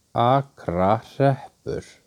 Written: Akrahreppur

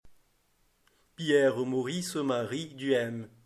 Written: Pierre Duhem

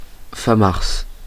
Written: famars

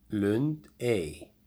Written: Lundey

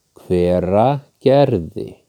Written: Hveragerði

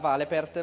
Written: Valepert

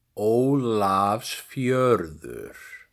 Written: Ólafsfjörður

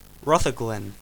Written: Rutherglen

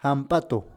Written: Hamp´atu

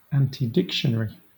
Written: antidictionary

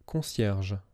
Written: Concierge

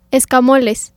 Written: Escamoles

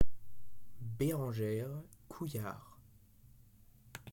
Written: Berangère couillard

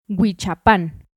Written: Huichapan

